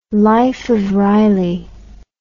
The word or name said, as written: life of riley